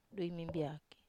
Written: dui mimbiaki

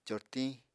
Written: cortiñ

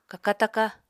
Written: kaka-taka

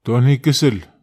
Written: tonihikesel

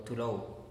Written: tu lou

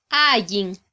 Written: ayin

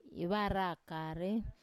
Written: ivarakare

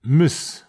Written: mes

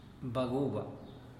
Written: baguba

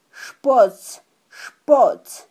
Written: xpotzxpotz

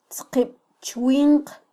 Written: tzqibchwinq

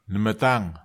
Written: nematag

